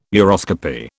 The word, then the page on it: uroscopy: British English